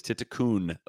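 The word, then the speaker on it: Tiitikun: But I've received an email confirmation that it even though it looks like Titikul, it is Titikun with an N is how you would pronounce it. So, which is my third different way of pronouncing it, but I believe that to be the correct one.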